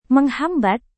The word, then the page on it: menghambat: Indonesian